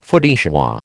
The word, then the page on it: feu de joie: American English